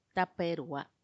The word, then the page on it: tappaerua: Macuiltianguis